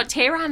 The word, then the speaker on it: Tehran: No, the University of Tehran, and I butchered that because I'm not even going to go back. – No, Tehran is right. You're right.